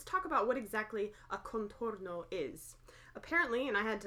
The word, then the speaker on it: contorno: It is pronounced Contorno. – Thank you. – As in container.